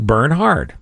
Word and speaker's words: Bernhard: It's Bernard. Just think Bernhard. It's a forest. – That's not a normal name. Bernhard. – Just think of it like a German pronunciation.